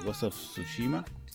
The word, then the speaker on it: Ghost of Tsushima: Bien, este es un juego que ya lleva varios meses eh, que se está esperando, eh, pero al fin, eh, Ghost of Tsushima, creo que lo dije bien, ¿Está bien sí.